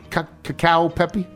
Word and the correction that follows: Cacio e Pepe: Oh, keep going with that one. Cacio e Pepe.